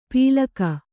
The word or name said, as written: ಪೀಲಕ